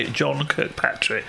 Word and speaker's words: John Kirkpatrick: This is the 1st of May, a folk song by John Kirkpatrick. – What's that? – I said it right, didn't I? – You did, yeah.